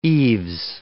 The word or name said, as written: eaves